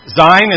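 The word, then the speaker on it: Zayin: Zain is Z as in zebra, Z as in zebra, and it's pronounced like Zion, Z-I-O-N. Zion, lovely Zion.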